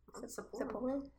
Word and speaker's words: Sapporo: Sapporo.